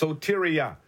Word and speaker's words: soteria: It's pronounced soteria. It's spelled S-O-T-E. R-I-A, soteria, S-O-T-E-R-I-A.